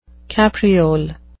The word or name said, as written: capriole